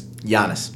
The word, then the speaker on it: Giannis: How do you say it? Guinness? – Giannis.